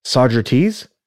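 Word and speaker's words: Saugerties: So the next file I have notes on is 4204 North, 7356 West, Sagittarius, New York, or Sagerties. That's not Sagittarius. That's Sagerties.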